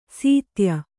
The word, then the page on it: ಸೀತ್ಯ: Kannada